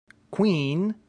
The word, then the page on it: quin: Latin